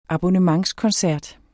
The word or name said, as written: abonnementskoncert